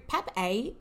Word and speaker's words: PAPP-A: So PAP-A, and that's spelled PAPP-A, mm-hmm.